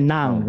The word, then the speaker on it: Ng: Believe no, because what was interesting was because in the Philippines, when you call a gentleman by the family name NG, like Mr. Nang, right? It's Mr. Nang. But apparently in Singapore, you pronounce him as Mr. Ang.